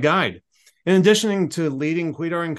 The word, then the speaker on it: Kwedar: And if you're wondering how to pronounce Kwedar, obviously here on audio, it's Kwedar. It's spelled K-W-E-D-A-R. Quite an interesting name. Just think Queen.